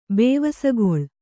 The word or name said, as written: ಬೇವಸಗೊಳು